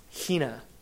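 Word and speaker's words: hina (ἵνα): That word is pronounced hina. Hina.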